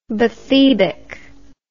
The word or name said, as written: bathybic